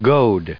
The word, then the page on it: goad: American English